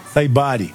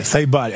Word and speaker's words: Saibari: Saibari. – Saibari? – Saibari.